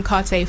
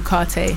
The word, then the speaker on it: Cartae: Okay, you can find me on Instagram, Carte Forever, Twitter, Carte Official, and I'm on Spotify, YouTube, Carte. – All that, and it's spelled C-A-R-T-A-E.